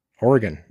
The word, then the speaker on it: Oregon: And then you try to say it and you're like, oh shit, how do you say Oregon? Oregon. That's what I'm saying. Oregon. It's like tarragon and oregano and all that stuff.